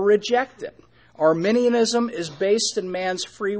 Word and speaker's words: Arminianism: Let me spell it. A-R-M-I-N-I-A-N-I-S-M. Arminianism. All right.